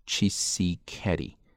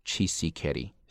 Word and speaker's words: Tshisekedi: 那么这个总统的姓呢不是特别好读，我们这 TS 呢它一般的读音呢是一个词，对吧？是一个清辅音的词哈，这个 H 呢可以直接给它击穿不读嘛，所以说是 Tshisekedi， Tshisekedi， Felix Tshisekedi。